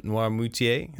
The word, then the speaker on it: Noirmoutier: And so I know a little bit about, I'm going to butcher this, but Noirmoutier.